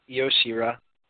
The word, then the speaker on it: Io Shirai: I'm going to pronounce her name wrong again, but is it just Io? Shall I? – Yeah, Io. Or Io Shirai.